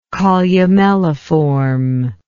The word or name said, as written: columelliform